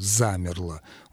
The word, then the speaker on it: замерло: Вот «замерло». Ударение падает на первый слог, и там прекрасно слышится «а».